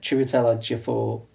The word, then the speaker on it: Chiwetel Ejiofor: But Chiwetel Ejiofor, he's from 12 Years a Slave.